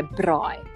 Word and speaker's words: braai: Yeah, but you got to say it properly. If you're going to say a braai, you must say a braai. We're going to braai. Ons gaan nou braai. – I can't even roll my R.